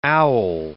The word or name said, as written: owl